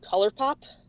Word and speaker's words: ColourPop: because I recently discovered this brand called ColourPop. It's spelled like the British Colour Pop. It's one word, and in my head, I always pronounce it Colour Pop, even though it's Colour Pop.